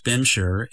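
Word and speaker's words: Bimshire: Bemshaw, or as it's more commonly pronounced, Bemshire, is a nickname for Barbados.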